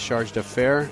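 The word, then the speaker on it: Chargé d'Affaires: He's serving in the capacity of the Charge d'Affaires. Did I say that right? – That's right. – It's kind of French, right?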